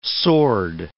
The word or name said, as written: sword